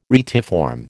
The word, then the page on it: retiform: American English